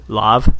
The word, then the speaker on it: Lauv: L-A-U-V. I haven't figured out how to say his name yet. – Lauv? – Lauv.